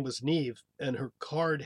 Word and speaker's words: Niamh: I mean, like the name, I'm looking at it right now, in Irish is spelled N-I-A-M-H, which I would think would be like Niam or something. And it's pronounced more like Neve.